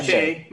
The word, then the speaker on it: Che: Yeah, Che.